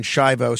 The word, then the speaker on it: Schiavo: Marlaina Schiavo is here. You could follow her on all forms of social media at Marlaina Schiavo. It's Marlaina, MARLAINA, and then Schiavo spelled like Terry Schiavo, SCHIAVO.